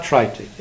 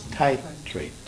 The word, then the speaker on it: titrate: She said I was pronouncing it incorrectly. It's not trite, it's titrate.